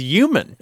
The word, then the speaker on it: human: We get emails frequently about the word human because there are certain people who don't pronounce the H. For example, Terry Gross says human without the H.